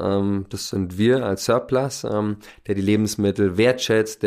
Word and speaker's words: SIRPLUS: Der Name, also äh, wenn man es mit U schreiben würde, würde es ja Surplus, genauso ausgesprochen, äh, oder auch auf Französisch Surplus, äh, das heißt Überschuss.